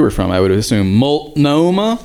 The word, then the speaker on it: Multnomah: Multnomah, just how it's spelled.